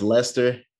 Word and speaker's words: Leicester: Yeah, pronounced Leicester.